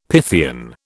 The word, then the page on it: Pythian: British English